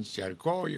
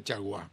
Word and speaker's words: Chargois: Aquí dicen Charcoy o Charcó. Se escribe Chargois y se pronuncia, es un apellido francés, se pronuncia Chargois.